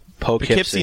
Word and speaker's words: Poughkeepsie: Poughkeepsie, New York.